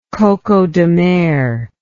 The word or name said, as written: coco de mer